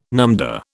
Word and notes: numdah: British English

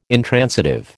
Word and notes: intransitive: American English

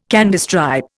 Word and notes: Candystripe: Indian

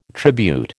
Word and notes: tribute: American English

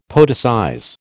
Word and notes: poeticize: American English

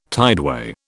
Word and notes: tideway: British English

